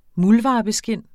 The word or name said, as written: muldvarpeskind